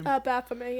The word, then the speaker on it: Baphomet: Is that how you say his name? – Baphomet, yeah.